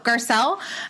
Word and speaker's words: Garcelle: Let's talk about Garcella. So Garcella. – No A. – Oh, Garcelle. Sorry.